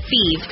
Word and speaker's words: Fives: Feeds is spelled F-I-V-E-S, like the number, but pronounced Feeds.